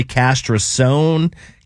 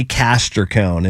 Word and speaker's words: Castricone: Although it doesn't look like that in the spell. I was going to say Castrosone. I didn't know where to go with it.